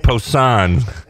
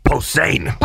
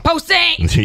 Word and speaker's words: Posehn: Yeah, you went French. – Did I? – You went Posehn. – Okay. – And it's German, so it's Posehn. – Posehn!